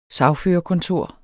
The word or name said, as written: sagførerkontor